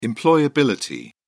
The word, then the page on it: employability: British English